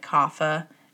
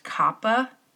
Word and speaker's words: kapha: And so I was reading a book the other day, and in the book, I finally learned, so I've mentioned this before, why I guess most everybody pronounces kapha, the kapha dosha, like kapha. And I guess I had heard somewhere that technically it's supposed to be pronounced kappa, like with an aspirated P, because it's K-A-P-H-A, because I learned this, finally I learned why. So in Sanskrit, there's no such F sound, and P-H does not make an F sound the way it does, like, in English and some other languages, but in English especially.